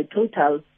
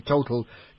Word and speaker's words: Total: Okay, but I understand that the drive to get people like us who would normally pronounce it from an English point of view and say Total to now call it Total and effectively drop the Total completely has become is a directive from headquarters.